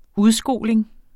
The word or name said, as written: udskoling